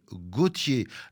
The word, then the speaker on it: Gautier: Gautier, prononcé Gautier, la traduction française de Wouter en somme, qu'utilisait d'ailleurs affectueusement sa mère.